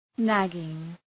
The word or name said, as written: nagging